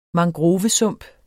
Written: mangrovesump

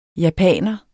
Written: japaner

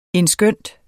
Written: endskønt

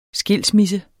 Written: skilsmisse

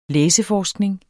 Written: læseforskning

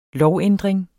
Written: lovændring

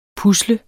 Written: pusle

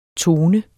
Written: tone

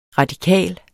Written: radikal

